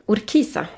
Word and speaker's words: Urquiza: Urquiza. Eh, Urquiza. Urquiza säger jag, vi ska ju ha argentinskt uttal såklart. Inte något spanskt läspande.